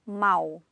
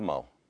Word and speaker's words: mal: Ele canta mal. – Mal. Mal, mal. Even though one's spelled with an L, one's spelled with a U, it sounds the very same.